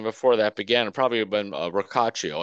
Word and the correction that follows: Rocchio: Is it Rocchio? It's Rocchio, right? – Rocchio. I'm sorry. Yeah, Rocchio.